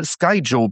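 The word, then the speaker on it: Skyjo: Irgendwann habe ich dann auch nochmal Sky Joe probiert. Das ist die korrekte Aussprache, ich habe es extra nochmal gegoogelt.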